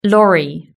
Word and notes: lorry: British English

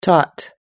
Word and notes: taught: American English

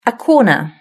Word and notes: a corner: British English

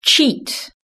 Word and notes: cheat: British English

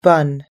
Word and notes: bun: American English

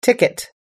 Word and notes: ticket: American English

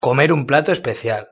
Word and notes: comer un plato especial: European Spanish

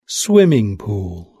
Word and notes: swimming pool: British English

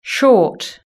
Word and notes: short: British English

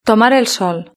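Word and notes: tomar el sol: Spain

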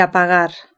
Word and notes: encender y apagagar: European Spanish

